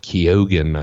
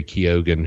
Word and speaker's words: Keoghan: And the one upside is Barry Keoghan, I think is the way that he pronounced his name. He was there at the festival. But Barry Keoghan, who's also in Dunkirk and some other films, he was great.